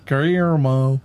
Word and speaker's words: Guillermo: I can't pronounce his name. – Guillermo.